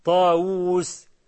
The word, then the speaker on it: طاووس: قال له سرق واو مولانا، ما هو سي داود احنا بنكتبها بواو واحدة وهي بتتنطق أو بالأصل تتنطق باتنين واو، لما تقول داود دال ألف واو واو دال، طاووس طا ألف واو واو سين ها، لكن احنا بنكتبها داود طاووس بواو واحده.